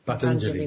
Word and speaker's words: Patanjali: So that was, I have always thought that they were related, but the Patanjali, is that how you pronounce it? – Patanjali, who wrote the Yoga Sutras.